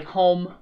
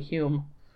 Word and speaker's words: Hume: It's spelled, it looks like home, but it's actually Hume.